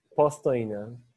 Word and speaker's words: Postojna: Postojna.